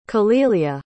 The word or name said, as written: kahlilia